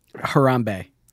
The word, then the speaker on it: Harambe: Harambe.